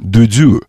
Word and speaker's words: Дудю: Дудю, я правильно говорю? – Дудю.